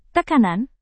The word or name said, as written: tekanan